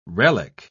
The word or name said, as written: relic